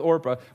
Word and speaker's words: Orpah: But everyone kept mispronouncing it, so they stuck with Orpah, or they went with Oprah rather.